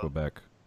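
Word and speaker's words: Québec: I believe it's pronounced so, Quebec.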